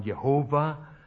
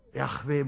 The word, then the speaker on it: Yahweh: Früher sagte man Jehova. Yahweh muss das eigentlich ausgesprochen werden.